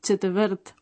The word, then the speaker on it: čtvrt: Otra palabra de difícil pronunciación es chetvrt, una cuarta parte.